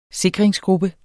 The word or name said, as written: sikringsgruppe